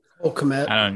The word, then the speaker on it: Kmet: How do you say that? – Cole Kmet. – Kmet. Yeah, they're my tight ends.